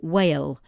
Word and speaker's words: whale: But it's W-H-A-L-E, whale, silent W, so sorry, silent H, whale. Matter, M-A-T-T-E-R, matter.